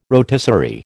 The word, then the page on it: rotisserie: American English